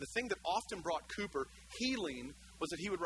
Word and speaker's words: Cowper: In fact, his name is spelled Cowper, C-O-W-P-E-R, if you look it up. But Cowper, he was clinically depressed.